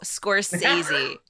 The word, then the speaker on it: Scorsese: So which recently released movie directed by Martin Scorsese? Am I saying that right? – Scorsese.